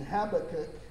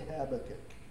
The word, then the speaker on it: Habakkuk: Some people say it different. I'm not a scholar. I just say Habakkuk. Okay, that's how I pronounce it. Habakkuk 2,